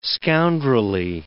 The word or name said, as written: scoundrelly